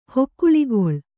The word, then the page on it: ಹೇಕುಳಿಗೊಳ್: Kannada